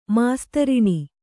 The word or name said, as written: ಮಾಸ್ತರಣಿ